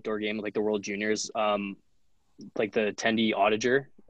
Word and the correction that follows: Oettinger: Is that how you say his name? Jake Audiger. He plays for Dallas, whatever. – Oettinger, yeah. Um, yeah every time he drink water, like it'd spill on it.